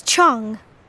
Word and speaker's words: Cheung: It's Chung. – Can you spell that? – C-H-E-U-N-G.